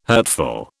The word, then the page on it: hurtful: British English